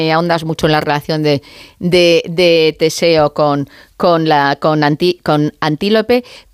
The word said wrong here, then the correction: Antíope: Pero lo he dicho mal el nombre, es Antíope. – Antíope, ¿verdad? – Antíope, o sea, Antíope, no, que la llama yo como un animal y no puede ser, Antíope.